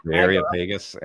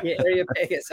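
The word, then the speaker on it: Areopagus: Area Pegasus. – Yeah, Area Pegasus.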